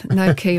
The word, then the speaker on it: gnocchi: No key, apparently. I think we've been saying knocky.